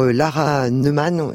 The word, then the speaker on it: Lara Neumann: Qui est incarnée par Lara Neumann, c'est comme ça qu'on prononce.